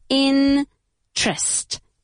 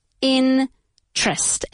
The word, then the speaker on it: interest: Okay, to take an interest, careful with the pronunciation, interest, interest. Es decir, la segunda sílaba se pronuncia como si fuera T-R-E-S-T, trust, trust, trust, interest, okay?